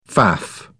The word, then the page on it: faff: British English